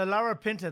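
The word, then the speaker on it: Larapinta: So La Rapinta, that's the name of the river, sis? – Yeah, so La Rapinta is the traditional name and the way to pronounce it.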